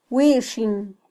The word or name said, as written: wixin